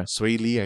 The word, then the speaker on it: Swae Lee: I don't know how to pronounce it. It's SWAE space LEE. – Right. Yeah. – Swae Lee, I think.